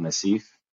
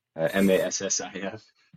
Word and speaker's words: Massif: So my civilian job, I work at a company called Massif, as most people pronounce it, as I pronounced it, MASSIF. And I got corrected when I was getting hired, and it's pretty funny. I was like eating my own foot. They're like, you know, it's Massif. And I'm like, yeah, totally.